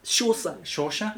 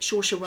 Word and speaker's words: Saoirse: And I don't think I don't think, how do you pronounce her names? – Saoirse? Yep, Saoirse Ronan.